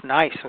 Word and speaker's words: gneiss: There's a material known as gneiss, which is spelled G-N-E-I-S-S, which is often sold as a granite.